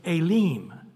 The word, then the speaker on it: Elim: It'll actually say Elim. So the E has an A sound and the I has an E sound, Elim. And then if you say, how do you pronounce S-I-N, it's actually seen is the way they pronounce it.